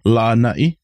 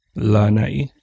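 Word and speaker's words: Lanai (Lānaʻi): Yeah, so Lanai actually has a kahako or over the first A, which is it's just a line over the vowels, and that just tells you to draw the vowel sound. So Lanai, Lanai. Yeah. So different from Lanai, yeah, but Lanai. Yeah.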